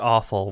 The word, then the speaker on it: offal: Like, I think it's pronounced awful, which is very awful.